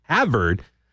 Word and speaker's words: Havard: But during that discussion, so the state rep from Louisiana, a guy by the name of Kenneth Havard, so it's almost like spelled like Harvard without the extra R, Havard.